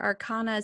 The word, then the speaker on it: arcana: I know that there's the major and the minor arcana. Is that how you pronounce it? Or is it arcana? – Arcana. Arcana. I like using arcana.